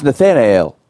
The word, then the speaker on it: Nathanael: Spell Nathaniel, disciple of Jesus. – Nathaniel. – No, dummy. – Ial. – No. No I. – Nathanael? – There's no I in it.